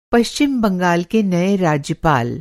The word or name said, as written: राज्यपाल